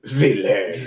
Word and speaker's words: Wilhelm: Yeah, since they're German, they pronounce it Wilhelm.